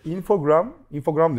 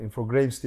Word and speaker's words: Infogrames: Infogrames diye okunuyormuş bu arada. Infogrames diye. – Infogrames değil mi? – Infogrames diye okunuyormuş.